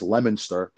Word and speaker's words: Leominster: they always get a kick because it's pronounced Lemonster, but it's spelled Leominster, and that's what we, that's what I called it when I got here.